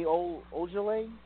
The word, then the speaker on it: Ojeleye: And then you have guys like Baines and Daniel Tice and Semi Ojeleye.